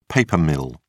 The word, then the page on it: paper mill: British English